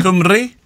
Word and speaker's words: Cymru: How do I pronounce this? Cum- Cymru? Cymru. do you have to roll your R as well?